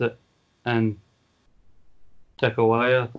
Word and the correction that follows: Takaiwa: I think it is. I may have butchered that name. – Tatsuhito Takaiwa. – There we go.